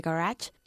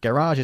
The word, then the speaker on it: garage: Cleaning the garage took up most of the morning. – Okay, garage or garage. Garage is how they pronounce it in the States, and in the UK we say garage, okay? – Okay.